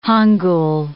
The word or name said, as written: Hangul